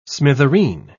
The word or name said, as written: smithereen